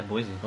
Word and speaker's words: Boise: Hello from Boise, Idaho. Since you, I think it's just Boise. Is it Boise or is it Boys? – Boise State. – Is it Boise? – Yeah, Boise.